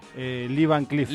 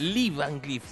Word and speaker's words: Lee Van Cleef: Seguramente lo pronuncio mal, como un montón de cosas que pronuncio mal, pero crea que Lee Van Cleef era. – Lee Van Cleef. – Lee Van Cleef, sí.